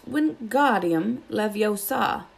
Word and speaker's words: Wingardium Leviosa: Wingardium Leviosa, he shouted, waving his long arms like windmill. You're saying it wrong, Harry heard Hermione snap. It's Wingardium Leviosa. Make the ga nice and long.